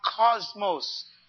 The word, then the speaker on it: kosmos: This word world in the Bible is translated from a word in the Greek, and it's spelled K-O-S-M-O-S. It is pronounced cosmos. Sounds familiar? Cosmos. I didn't say C-O-S. I said K. That's the way it's spelled in the Greek.